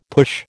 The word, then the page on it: push: American English